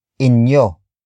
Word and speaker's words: inyo: In this case, it is inyo, inyo, inyo, inyo is spelled as I, N, Y, O.